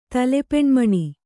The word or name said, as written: ತಲೆಪೆಣ್ಮಣಿ